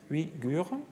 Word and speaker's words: Uyghurs: Comment ça marche ça Prenons l'exemple des Uyghurs je ne sais pas le prononcer, Uigur.